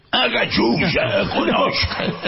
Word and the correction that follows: Cazuza: Cazuza. – No, pero se pronuncia más absorbiendo. – Cachucha. – No, absorbiendo las vocales, ¿no? – Cazuza.